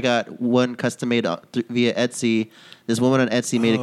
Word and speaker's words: Etsy: I think it's E-T-S-Y. – No, how you pronounce it. Because it's like, it's E-B, so it's eBay, but it's like Etsy.